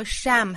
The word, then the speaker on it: sham: 那它的发音呢和 sham 很不同，这是一个双元音 a 后面再加鼻音，所以这个词发成 shame。虽然只差一个字母 e, 但是元音是不同的哈，一个是 sham, 伪善，一个是 shame, 羞愧。